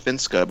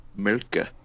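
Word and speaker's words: Mölkky: In Finland, and when the sport is like sold commercially, like overseas, it's called Finska. But what they call it here is Mölkkä, which is spelled MOLKKY.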